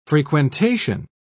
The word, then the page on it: frequentation: British English